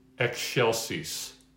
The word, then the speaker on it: excelsis: Excelsis. Excelsis, sort of like eggshells, but I like an EK, excelsis, excelsis Deo, not Deo, Deo, toward, you know, the short E, de, toward that, okay?